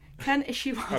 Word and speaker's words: Ken Ishiwata: And this is to celebrate 40 years of Ken Ishiwata. – 40 glorious years. – And I'm glad I said that right. Ken Ishiwata.